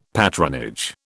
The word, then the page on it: patronage: British English